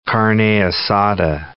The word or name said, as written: carne asada